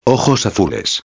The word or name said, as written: azules